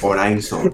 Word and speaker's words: Horizon: Pero ahora que lo pienso, ¿se pronunciará Horizon o Horizon o algo así? Mm. Horizon. – Horizon, es verdad, no lo he pronunciado bien.